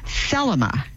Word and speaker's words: Thelema: And what she was asking about is something called Thelma. T-H-E-L-E-M-A was the emphasis on the first syllable.